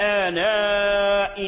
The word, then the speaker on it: آناء: نحو ومن آناء الليل، آناء الليل، بعد همزة آناء توجد الياء مرسومة في المصحف، هل تلفظ بهذه الياء؟ لا يجوز، ثابتة في الرسم محذوفة في اللفظ.